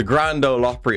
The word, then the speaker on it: Grand Ole Opry: So obviously, when I saw you playing the Grand Ole Opry, am I saying that right, by the way? – Yeah.